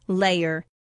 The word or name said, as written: layer